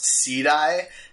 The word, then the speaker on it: SEEDI: But I guess it's pronounced SEEDI, S-E-E-D-I, the retro gaming system.